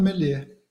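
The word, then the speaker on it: Merlier: E, Ele é e, belga, né? Então é Menier.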